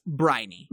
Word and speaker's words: Briony: It's spelled Briony, but it's Briny.